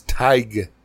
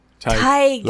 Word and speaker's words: Tig: You know how to pronounce his name? Is Tig. – Tig. – Like. Tigger, but Tig.